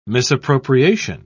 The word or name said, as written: misappropriation